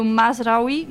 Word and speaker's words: Mazraoui: O lateral esquerdo, Mazraoui, não sei como é que fala, você deve saber a pronúncia melhor. – Não é isso mesmo, tá certo. – Ah, então, o Mazraoui,